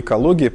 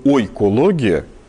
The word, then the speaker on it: экология: Интересно, что формально правильно слово «экология» по-русски произносить было бы «ойкология». И если мы откроем биологическую литературу начала 20 века или конца 19-го, мы увидим, что там это слово писалось прям «ойкология».